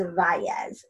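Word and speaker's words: Valles: My last name is Vales, so there's two L's in it.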